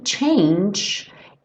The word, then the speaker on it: change: Aqui, change, esse G final tem um som tch, tch, como se fosse um DJ. Como o E final do change não é pronunciado, a gente junta no A.